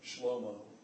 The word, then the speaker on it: Shlomo: Shlomo is the Hebrew pronunciation of the word of the name Solomon.